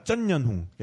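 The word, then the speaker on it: Trần Anh Hùng: 네, 그래서 그 트란이 아니라 그러니까 그 베트남 사람 이름 중에 뭐 트란얀훙 뭐 이런 사람 있는데 그건 트란얀훙이 아니라 쩐연훙입니다. 쩐연훙.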